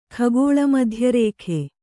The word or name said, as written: ಖಗೋಳಮಧ್ಯರೇಖೆ